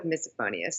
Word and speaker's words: misophonia: Well, I am a psychologist and a counselor in Connecticut, and I have, I call it misophonia. A lot of people call it misophonia. Some people call it misophonia. So there's a lot of different pronunciations, but I go with misophonia.